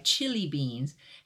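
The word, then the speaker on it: Chilli Beans: Yeah, Lemonade by Chili Beans, and it's spelled C-H-I-L-L-I, like chili.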